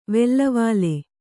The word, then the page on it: ವೆಲ್ಲವಾಲೆ: vellavāle